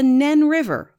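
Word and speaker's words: Nene: The two counties held a croquet match, and the winning team got to decide how to say the river's name. Northampton won 7-2, so it'll be the Nen River, but only for the next 12 months.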